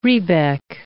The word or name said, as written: rebeck